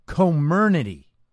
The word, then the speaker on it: Comirnaty: It's called Comirnaty, right? Apparently, that's the right way to pronounce the new brand name of the BioNTech Pfizer-like vaccine, right?